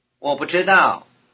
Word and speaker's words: 不知道: Notice that the last syllable is stressed. – 我不知道，我不知道，我不知道。